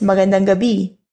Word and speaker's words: magandang gabi: And finally, we have the evening greeting, which in Filipino is magandang gabi.